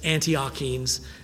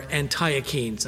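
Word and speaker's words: Antiochenes: The Antiochians, they were from Antioch, A-N-T-I-O-C-H, Antioch, and then E-N-E-S, Antiochians or Antiochians. I've heard it pronounced both ways.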